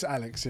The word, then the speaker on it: Alyx: It's spelled A. – It's with a Y. – Y-X, yeah. – But it's Alyx. Yeah.